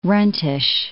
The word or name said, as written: runtish